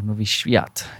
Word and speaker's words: Nowy Świat: And the Subway is actually in a street in Warsaw called Nowy Świat, or Nowy Świat, if I pronounce it better.